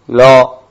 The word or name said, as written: ro